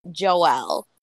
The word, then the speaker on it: Joelle: Her daughter, she actually named Joelle, but it's spelled J-O-E-L-L-E. – L-L-E. – Yep. – Yeah. Like Noelle, yeah.